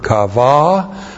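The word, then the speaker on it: qavah: This is the causal conjunction key plus the PL perfect of the verb kava with the second person singular masculine suffix.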